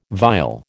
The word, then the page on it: viol: American English